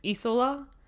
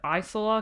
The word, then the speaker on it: Isola: He has a younger sister that when I looked up the pronunciation of this, they said it's Isola. When I looked at it, I would have said Isola because it's I-S-O-L-A. – Oh. – But apparently it's Italian and they say it's Isola.